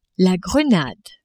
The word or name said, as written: Grenade